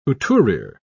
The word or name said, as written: couturiere